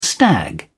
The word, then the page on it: stag: British English